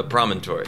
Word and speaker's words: Promontory: And is it Promontory? Promontory? How do you pronounce it? – Promontory. – Promontory. – Yeah. – That's how I would have pronounced it.